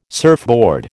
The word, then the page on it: surfboard: American English